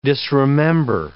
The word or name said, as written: disremember